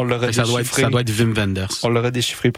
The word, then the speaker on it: Wim Wenders: Puis moi, je te pensais que c'était Wim Wenders. On pourrait leur demander comment prononcer. En fait, en fait, c'est toi qui as raison. – Parce que W en allemand, c'est V. Wim Wenders.